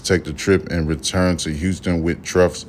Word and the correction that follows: Trung: mother. Trung's mother. Oh, I'm sorry. Hold on. Trung's mother. That's how you say her name.